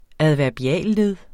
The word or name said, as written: adverbialled